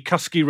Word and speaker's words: Cusky: And Angie Cusky, rhymes with husky, says, amazing book and brilliant interview with Heather.